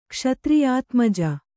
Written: ಕ್ಷತ್ರಿಯಾತ್ಮಜೆ

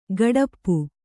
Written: ಗಡಪ್ಪು

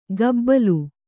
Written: ಗಬ್ಬಲು

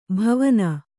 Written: ಭಾವನೆ

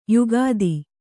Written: ಯುಕುತಿ

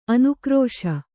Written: ಅನುಕರಿಸು

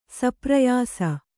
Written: ಸಪ್ರಯಾಸ

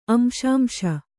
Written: ಅಂಶಾಂಶ

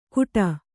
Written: ಕುಟ

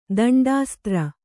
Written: ದಂಡಾಸ್ತ್ರ

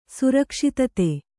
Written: ಸುರಕ್ಷಿತತೆ

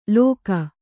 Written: ಲೋಕ

